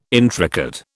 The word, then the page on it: intricate: British English